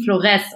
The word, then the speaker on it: Flores: Ich habe zwei Vornamen und das ist Celine Flores und die Betonung liegt auf dem ES hinten.